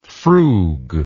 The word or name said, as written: frugger